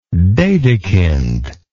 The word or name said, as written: dedekind